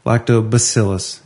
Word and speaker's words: Lactobacillus: He says it's pronounced Lactobacillus, and he had a WAVE file from the Bartleby Dictionary.